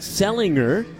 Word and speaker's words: Sellinger: Selinger spelled S-E-L-L-I-N-G-E-R, so it looks like Selinger, but it is pronounced Selinger.